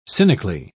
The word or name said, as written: cynically